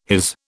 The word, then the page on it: his: British English